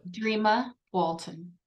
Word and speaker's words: Dreama Walton: I don't think I will, but Dreama Walton.